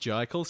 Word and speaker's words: Jickle: Well, I am J-I-C-K-L-E. It's pronounced Jikle, spelt Jikle on Twitter. – Mm-hmm. – And where are you? – I'm Nick Ibis, N-I-C-K-I-B-I-S.